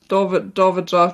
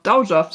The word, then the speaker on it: Dowager: Und weil sie ja verwitwet war und damit ja der Erbtitel an seinen Bruder ging, geht ja davor dieses Dowager. Nee, das habe ich auch falsch ausgesprochen. – Dowager. – Dowager. Dowager, so wird es ausgesprochen.